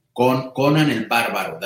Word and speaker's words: Conan el Bárbaro: Conan el Bárbaro, that's how you say it in Spanish.